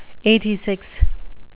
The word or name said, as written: eighty-six